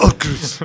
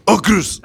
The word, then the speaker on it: Aukrust: Yo visualizo claramente que es Aukrust. – Pues, ¿por qué no? – Pronunciado así, tal cual. – Aukrust.